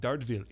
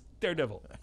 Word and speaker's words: Daredevil: It is not pronounced Dartville. It's Daredevil. We made the guy.